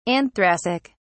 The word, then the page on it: anthracic: British English